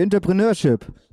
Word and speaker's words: Entrepreneurship: Jetzt kommt gleich das Zentrum für, du weißt es besser, es steht auf dem Zentrum für Entrepreneurship. Oh, das war gut ausgesprochen, das ist so ein schwieriges Wort.